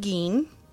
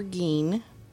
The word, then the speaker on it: Gein: Because I'm talking about Ed Gein, and that's G-E-I-N, and I've listened to many different things, and apparently that's how you say it.